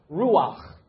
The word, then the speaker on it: ruach: It's from this word that means breath, and it's from a Hebrew word which is pronounced ruach.